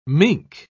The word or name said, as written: mink